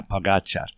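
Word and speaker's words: Pogačar: Now, someone told me today actually how to say his name. Pogacar. It's the accent on the second ga, Pogacar.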